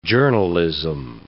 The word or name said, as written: journalism